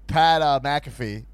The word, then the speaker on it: Pat McAfee: But Pat McAfee, I never think I'm saying his name right. I don't know. – You got it right that time.